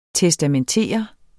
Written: testamentere

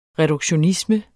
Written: reduktionisme

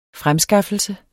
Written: fremskaffelse

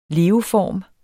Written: leveform

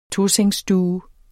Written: TOSENGSSTUE

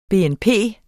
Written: BNP

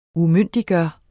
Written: UMYNDIGGJORTE

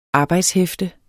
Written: arbejdshæfte

